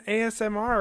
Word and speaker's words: ASMR: This isn't an ASMR or whatever the fuck these kids are calling it nowadays. – Good job. You said it correctly. And that took me months to learn.